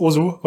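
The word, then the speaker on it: osu!: Ähm, das Spiel heißt Osu oder äh, eigentlich es Os ausgesprochen. Ähm, sorry. Weil es äh, aus dem Japanischen kommt, äh, aber total irrelevant.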